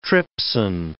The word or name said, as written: trypsin